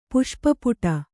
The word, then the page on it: ಪುಷ್ಪಪುಟ: puṣpa puṭa